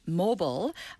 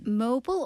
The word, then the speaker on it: mobile: 그리고 또 형용사는요, mobile, 움직일 수 있는 뭐 휴대용에 그래서 mobile phone. – Mobile or mobile. 자, 두 가지 다 발음이 가능한데요.